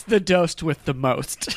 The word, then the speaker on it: DOST: D-O-S-T? – Yes.